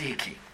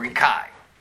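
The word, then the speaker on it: écu: E-C-U, pronounced ekü, or ekai.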